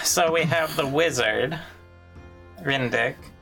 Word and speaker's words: Ryndik: His real name is Rydnik. – Sorry, I said that wrong. Rindick. – I'm emphasis on the dick. – R-Y-N-D-I-K.